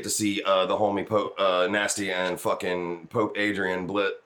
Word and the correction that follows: Adrian Bless: Oh my god, man, I said it wrong. Say the name, Paul. – Pope Adrian Bless.